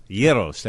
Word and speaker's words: gyros: And then last week we were talking about gyros. Gyros. Did I say it right, you guys? – Yeah, yes. You did.